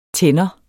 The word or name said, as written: tænder